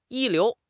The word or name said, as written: 一流